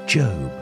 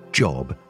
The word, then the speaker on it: Job: In the whole Old Testament, there was no one with a harder life than Job. By the way, Job is not a job that rhymes with cob, but Job that rhymes with robe.